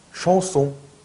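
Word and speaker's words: chanson: 예, 그런데 프랑스식으로 발음하시면 샹송. 샹 엉이니까. 예, 엉이니까요. 예, 프랑궁, 그러니까 한국식으로 발음 표기하실 때에는 앙이라고 표기를 하는데요. 그리고 그렇게 배우기도 해요. 그런데 그렇게 발음하시면 안 돼요. 그러니까 샹송 하면 안 되는 거예요. 샹송 하셔야 돼요. 샹송 하셔야죠.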